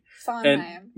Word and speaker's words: Sondheim: Sondheim. – We're clearly just bad at this. – Okay, it's the D is silent. It tricks you.